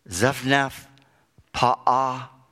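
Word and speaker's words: Zaphnath-Paaneah: Pharaoh gave Joseph the name Zaphnath-Paha'ah. I know what you're thinking. That's easy for you to say. But that was his name. Zaphnath-Paha'ah is the way you pronounce it, which means the abundance of life.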